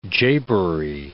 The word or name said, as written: japery